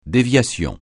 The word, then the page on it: déviation: francais